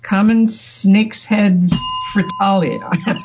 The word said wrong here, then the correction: fritillary: Fritillary. – Fritillary? Okay. – It's spelled FRITILLARY.